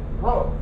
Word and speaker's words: rho: Rho.